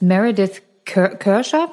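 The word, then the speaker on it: Meredith Kercher: Es ist so, dass Meredith Kirscher, wird die so ausgesprochen, Nina? Ja, ne? – Wie nennen wir sie Meredith.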